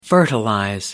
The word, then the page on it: fertilize: American English